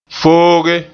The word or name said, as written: fooge